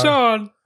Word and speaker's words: Sean: I'm Sean. – There you go. Now you had two syllables. You finally realized it. – Oh, so yeah. – I'm Sean.